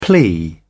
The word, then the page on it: plea: British English